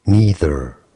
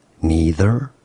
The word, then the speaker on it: neither: Neither. Neither. That's American English. Neither, British English, but it can also be said as neither in British English, neither, or neither. So, neither or neither in British English, or neither and neither in American English.